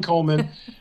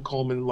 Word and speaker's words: Coleman: I am just not particularly small, Ron Coleman, who is a lawyer. Follow me on Twitter at Ron Coleman, like my name is spelled with an E, dum dum, R C O L E M A N.